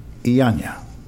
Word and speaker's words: Llana: and Jose Iaña. Do you think I said that right? L-L-A-N-A? – Thank you very, very much. – Yeah, I guess you got it right.